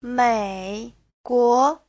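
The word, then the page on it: 美国: mei3guo2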